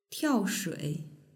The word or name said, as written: tiaoshui